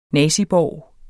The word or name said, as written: naziborg